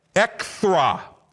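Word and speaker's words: ekthra (ἔχθρα): In the Greek language, which the primary language of the New Testament was written in, the word is ekthra. Let me spell it, E-C-H-T-H-R-A. E-C-H-T-H-R-A, ekthra, and that means hostility.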